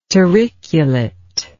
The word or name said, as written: turriculate